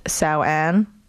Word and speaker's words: Samhain: And it's also, I think Halloween is also has roots in the Celtic holiday Samhain, or apparently it's pronounced Samhain. It looks like Samhain, but apparently you don't pronounce it like that.